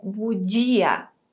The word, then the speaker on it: bugia: Então vamos caprichar na pronúncia aqui também. É bugia. Budia, capito? Bom, eu vou ficando por aqui, espero que você tenha gostado da nossa aula de hoje.